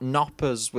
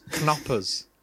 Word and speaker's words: Knoppers: But first thing I want to mention is it's not Knoppers with a silent K. It's Knoppers.